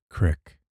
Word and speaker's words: creek: He pronounces it, crick.